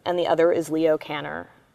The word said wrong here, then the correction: Kanner: um, or I should say Connor, um, is how he pronounces it.